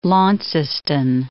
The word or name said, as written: Launceston